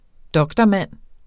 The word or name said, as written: doktormand